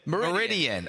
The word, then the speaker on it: Meridian: And then Savior of where? – Meridian. – Oh, so not Mirrodin, not Cameron, the plane of metal.